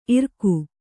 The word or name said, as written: ಇರಿಕೆ